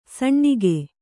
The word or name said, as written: ಸೀನ್ಕೆ